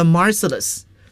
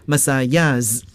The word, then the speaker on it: Marseillaise: 大家注意这个马赛曲啊，它的英语读法嘛，The Marseillaise。当然了，我们也可以采用它原始的这个法语的发音，Marseillaise，都可以啊。